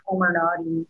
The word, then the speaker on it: Comirnaty: I looked up how to say it etymologically. I don't know what the etymology of this word is, but it's pronounced Comirnaty.